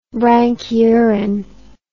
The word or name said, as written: branchiurous